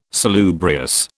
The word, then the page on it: salubrious: British English